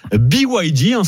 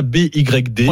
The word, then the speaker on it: BYD: Ça s'appelle BYD, ça s'écrit BYD, c'est ça, BYD.